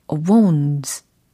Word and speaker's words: wounds: 특히 상처에 해당하는 부상에 해당하는 뜻의 단어로 wounds, wounds 요 단어를 활용을 해볼 거거든요. 발음이 예상치 못하셨나요? 어, W-O-U-N-D-S. 네, 복수형으로 말씀을 드린 겁니다. 그래서 wounds, wounds. 네, 이렇게 발음을 해주시면 되겠습니다. 최종 문장은 바로 이거죠.